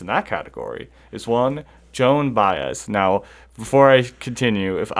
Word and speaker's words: Joan Baez: I know it's Joan Baez.